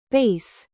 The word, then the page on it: bass: American English; noun